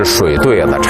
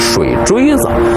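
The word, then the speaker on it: 水碓子: 这念起来啊，一定得是水对子，查字典也是水对子，但是北京人呢，都念成水锥子。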